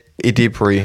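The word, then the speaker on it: Edebiri: Und das ist Ayo Ebori. Wie wird sie ausgesprochen? – Ayo Edebri? – Edebri, ja, genau.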